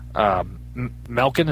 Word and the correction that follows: Minkin: is that how you say his name? – Minkin. – Minkin, yeah, Minkin.